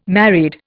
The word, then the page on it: married: Indian